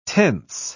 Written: tense

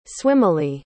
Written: swimmily

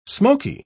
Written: smoky

